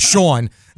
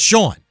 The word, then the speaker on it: Shaun: For me, Patrick explained to me how I should properly pronounce my name. So I'm going to go with Sean. Say it like a New Yorker would, Sean. Yeah, I learned like the New difference Yorker, between Sean. S-H-A-U-N and S-E-A-N and S-H-A-W-N.